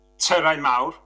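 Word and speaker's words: Tyrau Mawr: So, I'm just going to correct your pronunciation. It's a Terrain Mawr, which means big towers,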